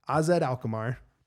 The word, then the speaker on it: AZ Alkmaar: He went over to AZ Alkmaar. Pretty sure I said that right.